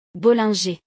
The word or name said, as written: Bollinger